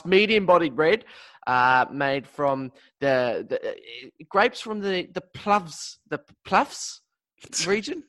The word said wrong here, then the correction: Pfalz: Am I saying that right? The Pluffs? P-F-A-L-Z. How would I say that? – Pfalz. Pfalz. Yeah, you know where that is.